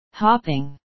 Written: hopping

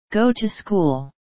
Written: go to school

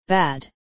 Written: bad

